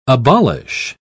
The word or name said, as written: abolish